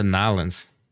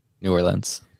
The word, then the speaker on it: New Orleans: Is it New Orleans or Nolens? – New Orleans.